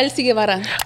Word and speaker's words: Elsy Guevara: Did I butcher that bitch? Did I say it right? – Yeah, Elsy Guevara. – I literally asked her like three times before we started. I was like, dime cómo se dice tu nombre because I naturally want to say Guevara.